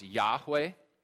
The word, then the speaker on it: Yahweh: So in the original Hebrew language, this name appears as four consonants, YHWH, to which we add vowels in between and pronounce Yahweh.